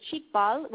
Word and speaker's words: Xikbal: And now, like I said, the Chikbal, which is spelled X-I-K-B-A-L, Baja, and Chikbal literally translates to,